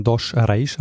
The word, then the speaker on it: dos Reis: ihn Alves dos Reis, aber im Portugiesischen, soweit ich mich informiert habe, ähm, spricht man den Namen dos Reis aus.